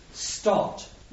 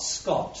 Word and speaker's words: Stott: Note, this name is Stott, not Scott. Scott is a very common English name. This name is pretty rare. So if you don't notice it, you can sometimes get the name wrong.